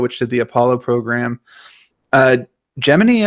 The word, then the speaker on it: Gemini: they'll continue on with the Gemini program, or the Gemini, as they pronounced it in First Man, and then the Apollo program, and presumably Apollo Soyuz, space shuttles, space labs, stuff like that.